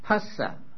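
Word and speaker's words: phassa: And in Pali, it is pasa. Now, PH in Pali should be pronounced pa and not as F in English.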